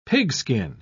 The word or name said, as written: pigskin